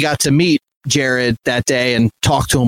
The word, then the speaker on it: Jahred: And real quick, I'm going to say Jared. It's spelled J-A-H-R-E-D. But I just listened to a podcast like a week ago interviewing him, and the podcaster kept calling him Jared and he never corrected him.